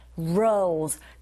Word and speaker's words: role: 이때 role 역시 발음하실 때 R 발음으로 시작하니까 우 하시면서 role. 근데 휴지 두루마리 할 때 그 role도 같은 role이거든요. 네, 철자는 다르지만 소리는 똑같다는 거 염두하시면서 기억해 주시고요.